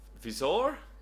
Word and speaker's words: Wieczor: and they found it when their last name was pronounced Wieczor, which I meant is pronounced Vizor. – Vizor. I kind of like that. – It's Polish.